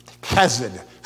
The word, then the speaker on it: chesed: And that's that Hebrew word, chesed. Chesed is how you say it.